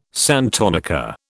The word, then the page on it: santonica: British English